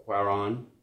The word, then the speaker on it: Cuarón: The movie this week that we watched is Y Tu Mama Tambien, the 2001 road trip movie by Alfonso Cuarón.